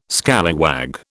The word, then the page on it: scallywag: British English